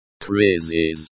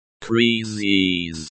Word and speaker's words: кризис: The pronunciation is krizis. The I in krizis sounds similar to the E in C. The S at the end sounds like the S in kiss, not the S in bus.